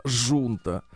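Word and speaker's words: junta: В некоторых других странах слово «хунта» читается иначе. Например, как? В Португалии была «жунта».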